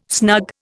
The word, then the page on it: snug: Indian